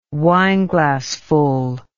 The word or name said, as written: wineglassful